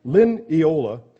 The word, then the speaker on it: lineola: You don't find them except the Acridium leonola. Nope, I said it wrong. Lineola, common in Baghdad, has a sting in its tail, according to Smith's Bible Dictionary.